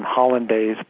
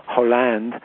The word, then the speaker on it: Hollande: I call him Hollandaise, but I know his name is pronounced differently than that. Hollande, I was told yesterday.